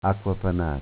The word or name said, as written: AcquaPanata